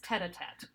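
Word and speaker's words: tête-à-tête: A titty. – Now, here's the thing. It's tete a tete.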